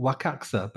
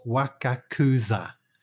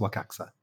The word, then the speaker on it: Wakakusa: It's called Wakaxa, but it's spelled Wakakuza. W-A-K-A-K-U-S-A, Wakakuza, but it's pronounced Wakaxa.